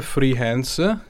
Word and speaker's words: Free Hands: Il gruppo si chiama MSQ and Free Hands, che si scriverebbe come mani libere, però se lo pronunci veloce è come dire friends, no?